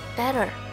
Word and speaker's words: better: 生活有了些小小的改变，我不看朋友圈，有了碎片时间学 ATT，知道了 better 在美语里念成 better，知道了 Betty bought a bit of better butter，我被语言的神奇深深吸引。